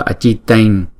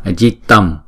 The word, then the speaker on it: Ajitam: Ajitain trong bài chú đọc là Ajitain, nhưng mà ở ngoài chữ bình thường chúng ta đọc là Ajitam, là nhóm vô năng thắng.